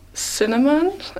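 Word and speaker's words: Synanon: I believe it's pronounced Cinnamon. It's S-Y-N-A-M-O-N.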